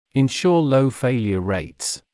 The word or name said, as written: ensure low failure rates